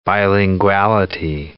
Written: bilinguality

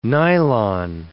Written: nylon

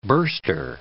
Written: burster

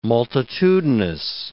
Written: multitudinous

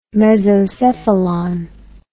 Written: mesocephalon

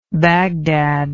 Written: bagdad

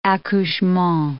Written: accouchement

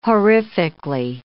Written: horrifically

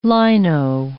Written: lino

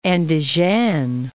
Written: Andizhan

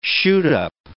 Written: shoot-up

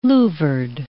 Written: louvred

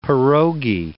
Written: pirogi

